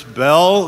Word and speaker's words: Bell: My name is Chris Bell. That's B E L L, spell it.